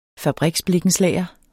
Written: blikkenslager

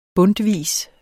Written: bundtvis

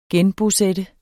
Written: genbosætte

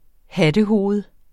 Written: hattehoved

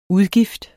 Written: udgift

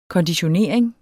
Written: konditionering

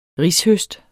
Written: rishøst

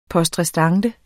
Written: poste

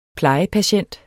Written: plejepatient

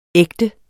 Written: ægte

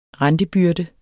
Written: rentebyrde

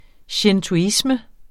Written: shintoisme

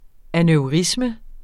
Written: aneurisme

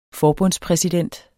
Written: forbundspræsident

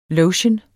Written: lotion

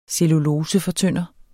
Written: cellulosefortynder

